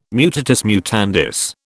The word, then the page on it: mutatis mutandis: British English